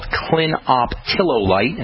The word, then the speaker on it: clinoptilolite: The most common one that you're going to hear about, the most well-researched one, is something called clinoptilolite, and that's spelled C-L-I-N-O-P-T-I-L-O-L-I-T-E, clinoptilolite.